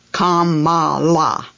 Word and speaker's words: Kamala: My favorite Les Nessman moment on air was when my reading partner, Joanne, said, "It's pronounced Kamala.